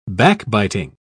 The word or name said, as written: backbiting